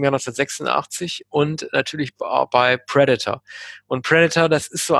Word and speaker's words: Predator: Oder wie Kinder, wie ich es damals waren, nicht Predator, sondern Predator gesagt haben. Man hat es damals mal falsch ausgesprochen in Deutschland. Predator, mal gesagt. Aber er heißt tatsächlich Predator, das Haupttier. Ja, also immer Predator, ne, weil das immer die Silber, die erste Silbe falsch betont.